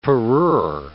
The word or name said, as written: parure